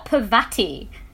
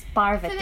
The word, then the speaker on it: Parvati: Which, by the way, as I'm here, like, that's not even how you pronounce it. It's not Parvati. Like, I just, at least say it right. It's Parvati, so like, while we're here.